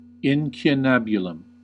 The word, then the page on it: incunabulum: American English